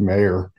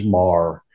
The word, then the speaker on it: Maher: We respond to both, and I have actually spent years just going along with Maher. – So is it Maher? – The official pronunciation is Mar.